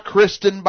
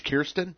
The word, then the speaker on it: Kyrsten: It's not Kristen, by the way. It's Kyrsten.